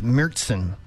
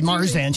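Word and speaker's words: Märzen: Sorry, my German-speaking daughter insists it's Mürzen. – Mürzen? – Yeah, that's the German pronunciation. She corrects me. I said Marzen.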